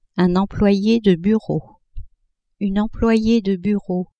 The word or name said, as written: employe de bureau-employee de bureau